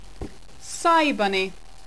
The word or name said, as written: cybunny